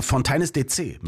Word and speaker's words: Fontaines DC: Und du hast es wirklich falsch ausgesprochen, weil es ist Fontaines DC mit Marianne.